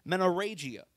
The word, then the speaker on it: menorrhagia: Y'all can pray with me right now, is menorrhagia. Did I say it right? Yes. That's awesome.